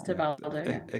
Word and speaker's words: Tebaldo: Is that a fair enough pronunciation of Tebaldo? – Yeah, it's Tebaldo. – Acceptable. – Meaning, if it was English, it would be a Tybalt, like in like Shakespeare.